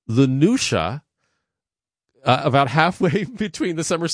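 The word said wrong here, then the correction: Lughnasadh: I'll do my best Irish attempt at Lunasa. – Lunasa.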